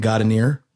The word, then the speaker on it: Gaudineer: We're in the basement with our good friend Dylan Gaudineer.